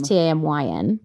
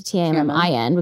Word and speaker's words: Tammin: Ooh, well, the spelling of my name is Tammin, but I changed it to Tammin because as a kid, no one could pronounce my name.